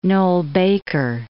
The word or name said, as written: Noel-Baker